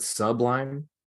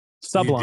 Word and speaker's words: Sublime: Did you just pronounce it Sublime? – Sublime. – You did. Yeah.